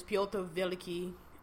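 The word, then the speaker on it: Pyotr Veliky (Пётр Великий): which in Russian is Pyotr Veliky and Catherine Veliky, that's how you say it in Russian,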